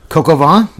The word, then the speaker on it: coq au vin: Get some beef burgundy or coq au vin. Am I saying that right? – Coq au vin, yeah.